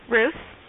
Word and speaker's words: Roose: Ruth. – Could you spell it for me? – R-O-O-S-E.